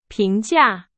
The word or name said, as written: 评价